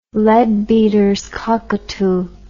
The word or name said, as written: leadbeaters cockatoo